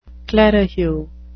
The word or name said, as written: clerihew